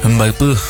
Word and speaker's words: Mbaipú: ¿Cómo se dice Mbaipú? – Mbaipú. Sí, en el sombrero.